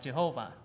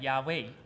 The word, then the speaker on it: Yahweh: Because in the language of Hebrew, it's not.The phonetic doesn't sound like Jehovah. – 而它的发音呢，如果按照字跟子音跟母音来发音的话，应该翻译成亚威。 – According to the Hebrew language, it actually sounds like Yahweh.